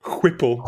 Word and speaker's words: Whipple: I'm sorry, Whipple? – Whipple, yes.